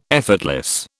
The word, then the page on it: effortless: British English